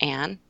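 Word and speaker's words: Anne: with an E on the Ann, the silent E.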